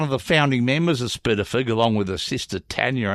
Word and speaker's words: SPDFGH: Spitterfug. – Spitterfug, which is S-P-D-F-G-H, so no vowels there.